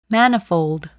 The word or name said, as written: manifold